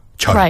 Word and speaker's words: 철: That throws Westerners like me for a loop, whether it's a hard J or a ch. – 철. Right, 철.